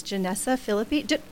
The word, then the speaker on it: Jenessa Fillipi: Her name is Jenessa Fillipi.